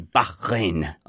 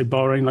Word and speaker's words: Bahrain: Yeah, you have to say it like that, don't you? Bahrain. Otherwise, you're not posh.